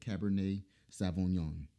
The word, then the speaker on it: Cabernet Sauvignon: I probably, my accent sucks, but I went to Google. I said, how do you pronounce this properly? And it said Cabernet Sauvignon. So there we go. Said it right this time.